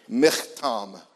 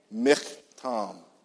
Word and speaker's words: Michtam: Because it's Hebrew. But it's you know, everybody say ich. Okay, that's what the sound is, okay? Michtam. Say that with me. Michtam. Say ich. Yeah, ich. That's the way you pronounce it.